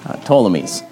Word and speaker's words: Ptolemies: It starts with a P, but you don't pronounce the P. Ptolemies.